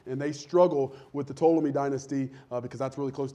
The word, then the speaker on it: Ptolemy: And so we get the Ptolemy. It's actually spelled with a P, P-T-O-L-E-M-Y, if you're taking notes.